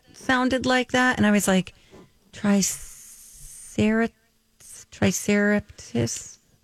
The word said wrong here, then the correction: triceratops: She said triceraptis. – Triceraptis. – I said, do you think it's triceratops? – and I said yes.